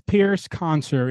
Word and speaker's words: Pyrrhus Concer: So his name is Pyrrhus Concer. It's spelled P Y R R H U S.